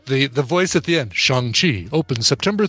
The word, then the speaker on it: Shang-Chi: By the way, I'm hearing the commercial pronounces it Shang-Chi and not Shang-Chi, like we've been, like I've been saying my entire life, Shang-Chi.